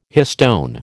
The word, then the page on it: histone: American English